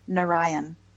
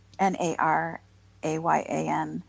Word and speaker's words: Narayan: Narayan, N R N A R A Y A N, by Nirinjan Kaur.